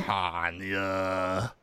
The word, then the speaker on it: Tanya: Tanya. That's such a cool name, Tanya. Tanya. – Thank you. – It rhymes with pinata.